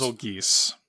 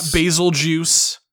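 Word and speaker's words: Bazelgeuse: is we got true canonical confirmation of how Bazelgeese is pronounced. – Bazelgeese. Not basil juice.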